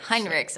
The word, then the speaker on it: Heinrichs: 所以其实呢，呃，在有一个作者，他叫做 J. Henricks. Am I saying that right? – Heinrichs. – Heinrichs. All right.